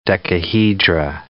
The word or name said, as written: decahedra